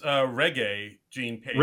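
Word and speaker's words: Regé-Jean: Next week on SNL, we got Gene Page. Am I saying that right? – I think it's Reggae Gene Page.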